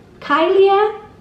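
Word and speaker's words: Caelia: So I am going to safely say that this name is pronounced Kylia, and it is a beautiful name with that L-I-A ending.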